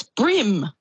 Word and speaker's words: bream: I've been saying Bream all my life. Is it not Bream? Is it Brim? – Oh, it's Brim. – Oh. – Brim, like on your hat.